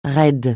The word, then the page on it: raid: francais